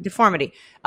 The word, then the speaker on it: deformity: And they no, I'm saying it incorrectly. Deformity.